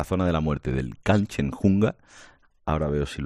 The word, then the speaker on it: Kanchenjunga: ¿He pronunciado bien Kanchenjunga? – La has pronunciado perfecto. La verdad es que no es una palabra fácil, pero la has pronunciado muy bien.